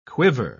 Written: quiver

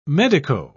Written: medico

